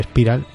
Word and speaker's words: Spiral: He, he buscado, he buscado cómo se pronunciaba Spiral, a ver si se va a decir Spiral y, yo estoy aquí overpronouncing, pero no, estaba en lo correcto.